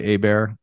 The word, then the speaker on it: Hebert: My name is Clay Hebert. The last name is spelled H-E-B-E-R-T.